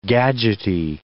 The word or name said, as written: gadgety